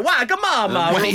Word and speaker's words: Wagamama: No, it's spelt Wagamama, and that's how it's pronounced.